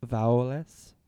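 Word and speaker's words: Voulez: That's Vaules.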